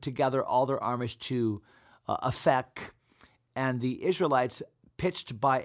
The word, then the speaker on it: Aphek: I think an episode or two ago, I was just in Israel, and the Jewish people, they pronounce that Aphek, Aphek.